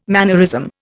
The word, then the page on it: mannerism: Indian